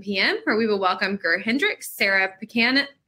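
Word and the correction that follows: Pekkanen: I always say her name wrong. Sarah Pekanen, right? – Yes.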